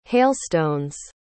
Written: hailstones

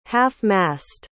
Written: half-mast

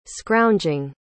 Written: scrounging

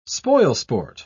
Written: spoilsport

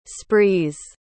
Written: sprees

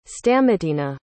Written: stamatina

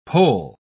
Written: pole

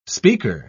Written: speaker

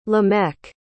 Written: lamech

